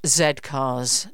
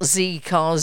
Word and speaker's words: Z Cars: It was, of course, Z Cars, pronounced Z Cars, not Z Cars, Z Cars.